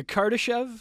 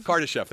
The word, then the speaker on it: Kardashev: And we're trying to understand, we want to sort of, you know, go beyond the sort of Karadash, if I can pronounce it right, the Karadashov. – Hold on, the Kardashev? – Kardashev, thank you.